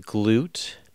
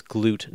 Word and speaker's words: Glut: Uh, we, I have a few interview clips from an interview with Don Glute. And I do want to apologize to Don for uh, pronouncing his name in this episode incorrectly. It's Glute, not Glut.